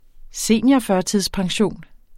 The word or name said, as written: seniorførtidspension